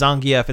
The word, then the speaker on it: Zangief: But it is like one of those popular like nomenclature kind of things where it works either way, but the official Capcom pronunciation is Zangief instead of Zangief.